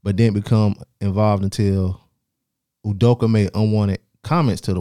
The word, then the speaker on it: Udoka: Nigerian Udoka, probably. – Ime Udoka. – Spell it. – I've been calling him Ume all week. – IMEUDOK. – Yeah, it is Udoka, but yeah.